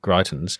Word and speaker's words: Greitens: Disgraced, disgraced former Missouri governor and GOP Senate candidate Eric Greitens, is that his correct pronunciation? Yes. Yep.